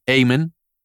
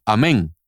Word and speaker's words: Amen: En inglés se pronuncia Amen, pero en español se pronunciaría Amén al igual que la palabra que decimos en la misa después de una oración, amén, así mismo, A-M-E-N. Daniel, amén.